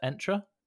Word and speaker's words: Entra: So yeah, our first episode is going to be around Microsoft Entra. Did I say it correctly, Alan? – Well, I believe you did, yes.